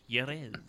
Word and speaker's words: Jerez: Say it with a Y. Jerez.